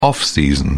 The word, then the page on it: off-season: British English